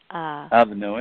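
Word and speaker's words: Alva Noë: Because I was also looking at I don't know how to pronounce the person's name, Alva Noe? – Alva Noe, yeah.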